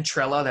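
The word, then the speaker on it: Trela: If you search Ben Trella, that's spelled T as in Tom, R-E-L-A, it'll come up with some of my older content that I made last summer as well.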